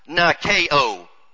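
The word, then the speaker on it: nikao (νικάω): Overcometh, nikao. You might want to put a few accents here. N-I-K-A accent. O, which is a long O.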